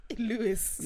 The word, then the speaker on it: Louis: Bro, it's Louis, fam. Louis Vuitton. – Louis. – Yeah, that's how you say it.